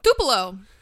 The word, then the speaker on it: Tupelo: It's Uncensored 1996, taking place on March 24th, 1996, from, how do you pronounce the city? It's Tupelo? – Tupelo.